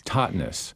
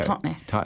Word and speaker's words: Totnes: Totnes. – T-O-T-N-E-S. – Yes, now I saw that on the map, and I was wondering how to pronounce that. Totnes. Okay. – Totnes.